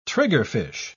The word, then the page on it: triggerfish: British English